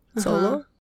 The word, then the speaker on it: Xolo: It's like the guy from what's it called, Cobra Kai, the guy that plays Miguel, his name is XOLO. Solo.